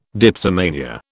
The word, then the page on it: dipsomania: British English